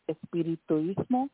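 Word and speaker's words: espiritismo: So you talk about Santeria and espirituismo, right? That's how you say it.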